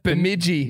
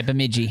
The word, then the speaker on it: Bemidji: Bemidji. – Yeah, Bemidji. – Hey. Crushed that one. – I was going to say Bemidji, but no, Bemidji.